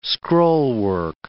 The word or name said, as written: scrollwork